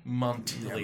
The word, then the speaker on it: Montpellier: Montpellier.